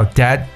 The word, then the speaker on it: debt: OK, debt, D E B T，我们知道这个单词 debt 表示债务的意思，特别注意一下这个单词里边的字母 B 是不发音的，debt 债。那么债台高筑呢，我们用一个形容词叫 debt ridden。